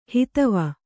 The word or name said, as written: ಹುದುವು